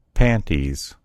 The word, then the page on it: panties: American English